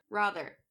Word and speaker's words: rather: The word G-R-A-N, how do you pronounce that? – G-R-A, Gran. – Okay, and then the word R-A-T-H-E-R, how do you pronounce that? – Rawther.